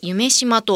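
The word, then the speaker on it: 夢洲: 会場は大阪市此花区の人工島夢島、ドリームの夢に東京八重洲の巣と同じ巣を使って夢巣と書いて夢島と読むんですね、はい。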